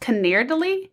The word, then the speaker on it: Canederli: So it's pronounced Canerdily. It's like C-A-N-E-D-E-R-L-I.